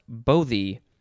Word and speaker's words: bothy: Next is bothy, or it could be pronounced bothy, B-O-T-H-Y, noun from 1771.